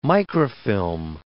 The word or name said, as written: microfilm